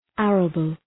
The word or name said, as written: arable